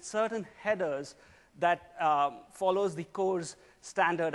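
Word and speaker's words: CORS: The other thing that we're announcing is cores. Yes, it rhymes with doors.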